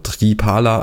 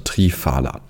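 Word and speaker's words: Triphala: Dieses Wort ist Sanskrit und im Sanskrit gibt es kein F. Also es gibt viele Wörter, die mit PH geschrieben werden, aber die werden nicht mit F ausgesprochen, sondern man sagt P, also Tripala anstatt Trifala. Es gilt auch für andere Wörter wie Kappa anstatt Kaffa.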